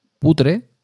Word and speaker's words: putre: Putre. Así como suena, putre.